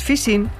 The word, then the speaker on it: phishing: Recuerde la expresión popular, por la boca muere el pez, pues phishing se pronuncia en inglés casi como fishing, nuestra pesca.